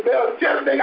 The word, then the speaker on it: Melchizedek: how do you say Melchizedek? It doesn't matter. It's Melchizedek. I always say Melchizedek.